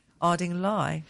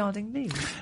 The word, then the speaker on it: Ardingly: Southeast Construct Expo, which the 2021, is going to be Tuesday the 28th of April at the South England Showground in Ardingly, West Sussex. – Ardingly. – Why can't it be Ardingly? – Because it's in England, and you should know by now, we always pronounce place names in the south of England weirdly, you know, like yeah.